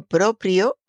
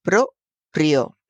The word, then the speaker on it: proprio: Eh, fíjese, motu propio se escribe como lo estoy pronunciando, propio, con R en las dos sílabas, propio.